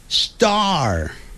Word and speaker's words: star: 不要管它，这些字都不要管，我们只要学会 Star。Star，因为 STAR 有一个 R 的音结尾的时候然后要卷舌的，而且 S 后头有那个 T 的时候可不要念成 star，那个 T 就变音了，变成 D 的音 star star。